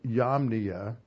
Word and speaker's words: Yamnia: Yamnia. It's spelled like Jamnia, but in the Hebrew it's with a Y.